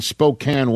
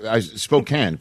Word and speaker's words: Spokane: Charles in Spokane, Washington. I said it right, Charles, right? – Hey, Rich, how's it going? – Spokane, correct, right? – Yeah.